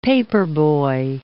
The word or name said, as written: paperboy